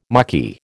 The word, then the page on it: mucky: American English